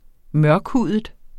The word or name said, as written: mørkhudet